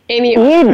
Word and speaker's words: Amy Oestrecher: Amy Oestrecher.